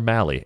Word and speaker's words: Mahle: Um, and apologies for the hundred million times I said Tyler Molly over the years. That was one that I just flat out had wrong. So thanks to the uh, pronunciation guide, it's Tyler Mahle, and it has been corrected.